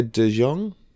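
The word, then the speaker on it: DeJonge: Olivia De Jong.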